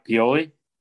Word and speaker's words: Pioli: I think you didn't say his name right. You said like Scott. – Yeah, because I said Scott, or something. I said Scott Piolian and Bill Poley or whatever.